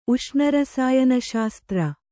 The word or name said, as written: ಉಷ್ಣರಸಾಯನಶಾಸ್ತ್ರ